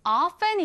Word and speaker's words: often: 자, 발음할 때는요, 두 가지 방법이 있습니다. 첫 번째는 이 t를 살려서 often. 두 번째는 t 발음을 생략한 채로 often이라고 하는데요. 여러분은 often, often 어떤 것이 더 마음에 드시나요? 사실은 두 번째 t를 생략한 채로 발음하는 것이 훨씬 더 흔히 사용되긴 합니다.